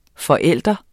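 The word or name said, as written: FORÆLDEREN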